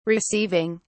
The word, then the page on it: receiving: British English